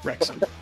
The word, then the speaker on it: Wrexham: My friend Rob and I bought a soccer team and made a show about it called Welcome to Rexham. Wrexham. the H is silent.